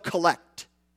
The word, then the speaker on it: collect: It's spelled collect, but in church circles, when it is used to reference a prayer, it's pronounced collect.